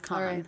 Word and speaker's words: Cannes: No, it's pronounced Cannes.